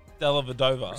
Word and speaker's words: Dellavedova: That's why I said Dellavedova. – Respect. – There we go. Said it right.